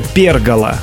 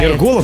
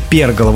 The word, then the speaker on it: пергола: Давайте, первое слово – это пергола. Вот ударение на «е». Пергола. – Ой-ой-ой, я знаю это слово, но я не помню, что она обозначает. – Кто-то говорит пергола, но это пергола.